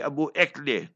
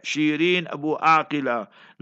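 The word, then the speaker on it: Shireen Abu Akleh: That lady who they murdered, Christian lady, Abu Ekle. No Abu Ekle, Shirin Abu Aqila. Learn to pronounce correctly.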